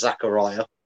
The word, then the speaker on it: Zakaria: First of all, I think his name's pronounced Zakaria.